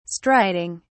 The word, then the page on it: striding: British English